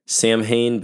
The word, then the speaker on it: Samhain: spelled S-A-M-H-A-I-N, so it looks like Samhain, but apparently it is pronounced Samhain.